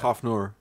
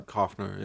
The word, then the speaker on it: Coughenour: C-O-U-G-H-E-N-O-U-R. How would you pronounce that? – Koffnor. – Koffnor? – Or Koffner, yeah.